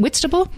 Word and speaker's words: Whitstable: Is it Whitstable? – You're saying it exactly right, yeah.